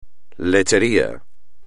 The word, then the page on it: lechería: espagnol